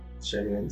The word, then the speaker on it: Shyamalan: sabemos eh, que el tipo de cine de Shyamalan, ¿cómo se dice, amigo? Shyamalan, sí.